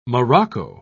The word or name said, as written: morocco